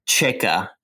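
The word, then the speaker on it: Čejka: I'm saying his name wrong. I can never say anyone's name right on this podcast, Alex. Say it for me because I just can't do it right. – Alex Chica.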